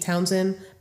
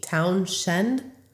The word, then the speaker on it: Townshend: I want to say Pete Townsend, but it's Pete Townshend.